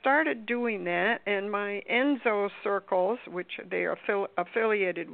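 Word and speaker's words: ensō: I think Enzo is the correct pronunciation. It's E-N-S-O.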